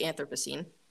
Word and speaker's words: Anthropocene: It's just the Anthropocene. – Anthropocene? – Yeah, okay. There's no emphasis in that O. – Anthropocene. – It's okay. – Oh, it's embarrassing. – No, it's okay. – Anthropocene Reviewed.